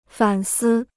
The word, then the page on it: 反思: fǎn sī